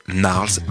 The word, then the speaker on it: Gnarls: En fait, un petit truc, vous oubliez qu'il y a un G au début du nom. Il ne se prononce pas, du coup, ça fait Gnarls Barkley.